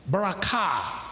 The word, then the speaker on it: barakah: So the word blessing, or the word blessing, is from the Hebrew word barakah. Barakah. I spell it B-A-R-A-K-A-H. The accent is on the K-H.